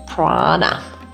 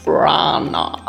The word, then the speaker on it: Prana: Natürlich ist, wenn wir das jetzt in unserer westlichen Welt aussprechen, mit auch Prana up your life einfach mhm. schöner. Aber die originale Sprechweise ist tatsächlich Prana. Prana.